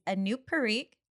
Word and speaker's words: Anup Parikh: And our guest today is Anup Parikh.